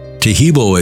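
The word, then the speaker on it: Taheebo: Tahibo is spelled T-A-H-E-E-B, like boy, O, then the word tea,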